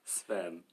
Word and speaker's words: Sven: No. There is a Sven that's pronounced with a W, but mine is with a V. – Okay. – Oh, like S-W-E-N? – Mm-hmm.